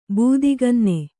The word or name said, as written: ಬೂದಿಗನ್ನೆ